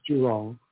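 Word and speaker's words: Zhurong: So when you see ZH, it's like a J, so it's Jurong.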